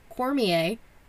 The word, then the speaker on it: Cormier: Daniel Cormier, spelled like Cormier, but there's no, you don't pronounce the R, guys.